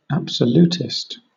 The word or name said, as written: absolutist